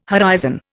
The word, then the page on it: horizon: Indian